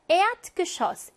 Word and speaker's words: Erdgeschoss: Erdgeschoss, 1층이라는 이 단어, 밑줄 친 에, 무조건 장음이에요. 이거 잘 기억하셔야 돼요.